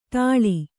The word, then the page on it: ಠಾಳಿ: kannada